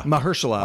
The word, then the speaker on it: Mahershala: Mahershala Ali, who is just one of the greats.